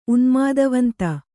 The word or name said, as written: ಉನ್ಮಾದವಂತ